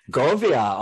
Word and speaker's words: Govea: Oh, it's actually Govea. – Govea.